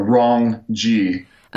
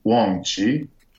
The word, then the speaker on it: 忘记: So a wrong G would be, Okay. – I mean, it's close enough. So then you obviously have to know how to say it correctly, which I guess would be Wong Chi.